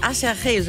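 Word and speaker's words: Aserejé: Schon von einer Combo namens Asherche, so wird die ausgesprochen, aber sieht aus wie Ach Herrje.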